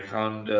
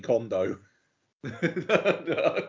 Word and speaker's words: Condon: Okay, sorry, I thought you said Kerry Condo. – No, Kerry Condon.